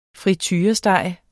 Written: friturestege

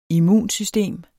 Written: immunsystem

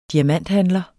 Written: diamanthandler

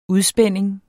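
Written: UDSPÆNDING

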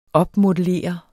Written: opmodellere

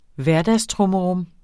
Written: hverdagstrummerum

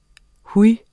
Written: huj

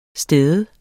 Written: STEDE

